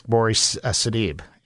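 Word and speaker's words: Gabourey Sidibe: How do you pronounce that name? – I believe it's Bori Sadeeb for Precious.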